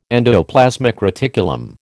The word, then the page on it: endoplasmic reticulum: American English